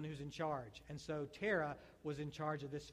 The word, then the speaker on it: Terah: And it stands for Terah. Rhymes with Sarah. Begins with a T.